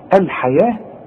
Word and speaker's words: الحياة: اشتروا الحياة، الحياة مكتوبة بالواو ولكن تنطق بالالف، ورسم لك الف فوق الواو بيقول لك انطقها الف كاملة، الحياة، لكن اذا كتبتها تكتبها بالواو.